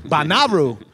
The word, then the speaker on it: Bonnaroo: I met Shane at Bonnaroo. Why am I pronouncing it like that? I'm French. – Bonnaroo.